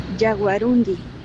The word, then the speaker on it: yaguarundí: Pues nosotros decíamos por el acento yaguarundí, sin embargo... – Es así. – Es yaguarundí. – Yaguarundi.